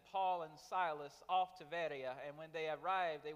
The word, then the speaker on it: Veria: It's spelled Berea, but it's pronounced Veria. And in Acts 17:10 through 15, we read of his work there in Veria.